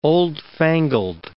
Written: oldfangled

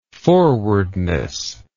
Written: forwardness

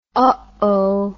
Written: ohoh

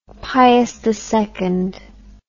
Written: piusii

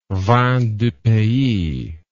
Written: vin du pays

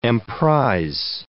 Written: emprise

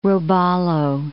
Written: robalo

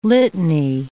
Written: litany

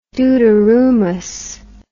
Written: dooteroomus

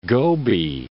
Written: Gobi

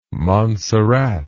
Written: monsarrat

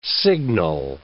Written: signal

